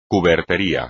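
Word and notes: cubertería: español

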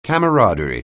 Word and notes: camaraderie: anglais

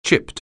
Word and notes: chip: anglais

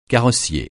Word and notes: carrossier: francais

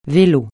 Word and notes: vélo: francais

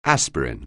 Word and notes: aspirin: anglais

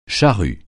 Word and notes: charrue: français

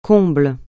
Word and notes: comble: francais

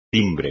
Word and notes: timbre: espagnol

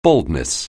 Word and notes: baldness: anglais

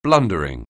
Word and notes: blundering: anglais

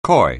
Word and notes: coy: anglais

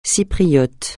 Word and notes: Cypriote: francais

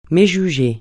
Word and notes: méjuger: francais

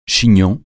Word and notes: chignon: français

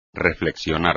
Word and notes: reflexionar: espagnol

